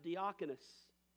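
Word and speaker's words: diakonos (διάκονος): The word deacon, as written by the Greek, is pronounced diakonos, or a body of diaconate.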